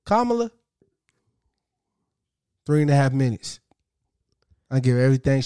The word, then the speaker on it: Kamala: It's Kamala, but I call her Kamala, nigga. That's how I pronounce it. – You're almost saying caramel.